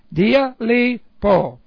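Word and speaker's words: dialeipo: This is D-I-A-L-E-I. Dialipo. That's a long O.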